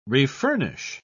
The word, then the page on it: refurnish: British English